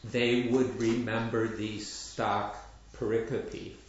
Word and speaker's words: pericope: I've been told, that's how I said it, pericope, and now I was told, no, it's pericope.